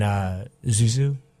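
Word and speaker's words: Xiu Xiu: And Zuzu? Am I saying that right? – Yes. Yes, you are.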